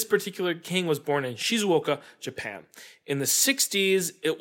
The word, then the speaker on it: Shizuoka: And I looked it up, and it says, oh, your tone shifts down. That's the way this is pronounced in this language. So the first half is Shizu, and the last half is Oka. And it's like supposed to be a Shizuoka. Like your tone is supposed to go down.